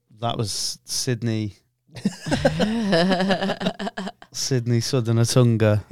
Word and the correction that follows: Sudenagunta: Did I say it right? No. – For the purposes of treason, yes, you did. – Oh yeah. How would I say it? We'll bleep it. – It's Sudenagunta. – Sudenagunta? – Yeah. – Oh, nearly there. Put a little spoonerism in there.